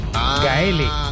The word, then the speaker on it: Gaelle: Pero es que este se escribe G-A-E-L-L-E. – Gaele. – Gaele.